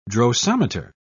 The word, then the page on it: drosometer: British English